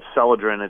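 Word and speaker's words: Celadrin: One is the ingredient's called Selodrin.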